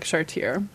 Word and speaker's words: Chartier: Well, It's funny because, you know, the way you spell her last name, C-H-A-R-T-I-E-R, so it looks like Chartier. But in French, you often don't pronounce the last consonant, so it's Chartier.